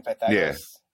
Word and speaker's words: Pythagoras: Is that how you say his name? Pythagoras. – Yeah.